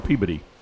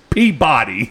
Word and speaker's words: Peabody: He goes, what, 6421 Peabody. I went, Peabody? – Peabody? He goes, yeah, Peabody. I said, can you spell that? He goes, P-E-A-B-O-D-Y. I said, oh, Peabody.